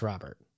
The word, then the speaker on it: Rhobert: I think it's still pronounced Rhobert. – Oh, it is? Okay. – Yeah. Yeah. – It's got a cooler spelling.